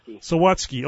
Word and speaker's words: Sawatsky: Yeah, Sawatsky. – Okay. I kind of maybe had it right a time or two. – Just like it's spelled.